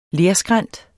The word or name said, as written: lerskrænt